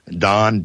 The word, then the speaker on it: Don: Okay, my Facebook page, my name is spelled Don,